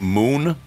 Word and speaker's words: Munn: Uh, from, if I'm pronouncing it right, Moon, but it's it's spelled M U N N.